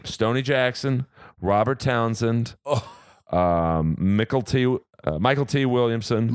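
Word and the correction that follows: Mykelti Williamson: Micheletti Williamson. – Michael T. Sorry. – That was the whitest way to say his name.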